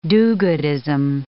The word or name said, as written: do-goodism